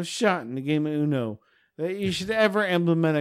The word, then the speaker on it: Uno: And the game of Uno is supposed to teach kids numbers. – How do you say that? Say the game of what? – Uno. – You kept saying you know.